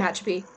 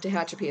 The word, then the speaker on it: Tehachapi: At least that's how they pronounced it in the episode. Tehachapi is how they pronounced it, so.